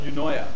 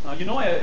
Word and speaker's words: Eunoia: I'm going to read to you some excerpts from my book entitled Eunoia. Eunoia is spelled E-U-N-O-I-A.